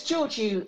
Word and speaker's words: Georgiou: It's Georgiou. It's my husband's name. He's from Cyprus.